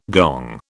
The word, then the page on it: gong: British English